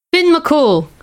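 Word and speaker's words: Fionn mac Cumhaill: Fionn Mac Cumhail. – Finn McCool, everyone.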